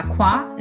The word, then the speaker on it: Kwa: Everything I do creative, I go by Qua. It's my branding name. Spelled with a K, K-W-A, guys.